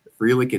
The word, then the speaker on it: Frelick: You studied your pronunciation guide previously because some might say Frelick, but Frelick it is.